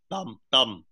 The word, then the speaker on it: Tam: I think it's important for the audience to know that although my name is pronounced Tam in English, in Vietnamese, it's pronounced Thum.